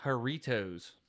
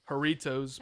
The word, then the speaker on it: Jarritos: I think it's pronounced Jarritos. – Jarritos, possibly.